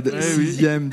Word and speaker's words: sixième: Deux mots dans lesquels la lettre X se prononce Z, sixième, dixième.